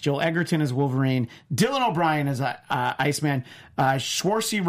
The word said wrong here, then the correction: Saoirse: Ronan, I know I said her name wrong, as Jean Grey.